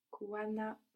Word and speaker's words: kuwa na: Good, but you pronounce kuwana like it's one word, so I just want to clarify that it's two. Kuwa na, and then you have like kind of two different stresses.